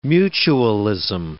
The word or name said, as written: mutualism